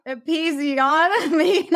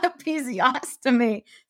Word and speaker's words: episiotomy: A, episiotomy, not episiostomy.